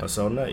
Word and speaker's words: Hosoda: This is made by, and I'm gonna butcher this name here, Mamoru Hosoda. – Yeah. Hosoda, yeah.